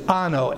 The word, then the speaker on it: ano: It's ano, A-N-O, long O, ano.